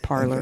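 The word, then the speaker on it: Parler: I always say parlay because parlay. It's French, okay. But maybe they pronounce it parlor.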